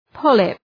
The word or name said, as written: polyp